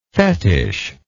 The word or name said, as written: fetishlike